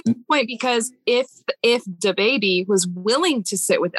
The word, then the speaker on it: DaBaby: Yes, I said that right. DaBaby.